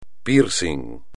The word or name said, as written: piercing